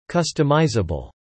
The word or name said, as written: customizable